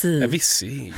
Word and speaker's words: Avicii: Avicii.